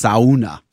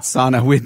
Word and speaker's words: sauna: As predicted, somebody has corrected my pronunciation. It's sauna. – Sauna. – Not sauna. Jenny and I were laughing about that. And then sure enough, thank you, listener. I know I don't say it like the Finns.